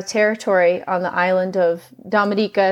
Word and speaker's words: Dominica: There's the Dominican Republic, and then there's Dominica. Commonly, people mispronounce it, but it's Dominica, should be called the Waitukubuli Island.